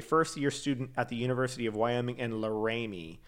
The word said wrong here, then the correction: Laramie: Is it Laramie? Okay. Sorry. on the accent on the wrong syllable.